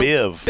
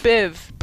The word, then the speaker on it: Biv: Sometimes I say that, and sometimes, I mean, you threw me off because I was saying Bill Bev DeVoe wrong this morning. – Yeah. You said that wrong. It's Bill, Biv. – Biv. Bell Biv DeVoe. – Not Bev DeVoe.